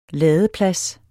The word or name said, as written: ladeplads